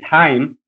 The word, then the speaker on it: Chaim: Well, actually, yeah, it's our friend, his name is Chaim in Hebrew pronunciation.